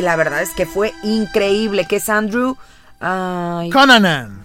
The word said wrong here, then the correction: Cunanan: Andrew Cunanan.